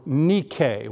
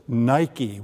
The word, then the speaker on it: Nike: The name Veronica in Greek means woman of victory, or Nike, which is the Greek word for victory, although here in America we pronounce it Nike when we go shopping for our shoes, victory.